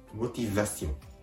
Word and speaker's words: motivation: フランス語で動機はモティザシオン。